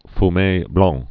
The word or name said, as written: Fume Blanc